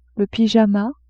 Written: pyjama

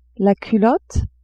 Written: culotte

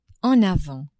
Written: en avants

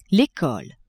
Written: ecole

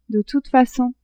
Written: de toute facon

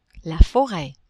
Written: foret

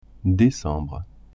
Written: decembre

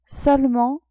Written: seulement

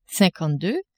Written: cinquante-deux

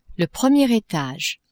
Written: premier etage

